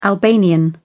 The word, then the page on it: albanian: British English